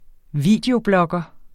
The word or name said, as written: videoblogger